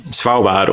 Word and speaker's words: Svalbard: Svalbard or Spitsbergen.